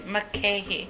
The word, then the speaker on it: Mulcahy: Mulcahy.